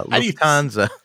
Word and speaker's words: Lufthansa: Lufthansa.